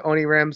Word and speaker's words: Onirim: I am going to go with how he pronounces it when he does interviews. – Okay. – So Onirim. I've heard him do a couple of interviews. He pronounces it Onirim.